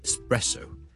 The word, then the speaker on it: espresso: 今天有个朋友叫笑叔叔讲一讲这个话题就是呢有两个字 ES 字开头的分别是 ESPECIALLY especially 即是特别地呢尤其是呢 especially 第二个字呢都是 ES 开头的就是 ESPRESSO espresso espresso espresso 即是意式的特浓咖啡我们这两个字的 ES 呢我们都不要将它读成 X 不要 espresso 不要 especially but espresso E 或者 E 都得直接加个 S 中间唔好加咗个 K 音，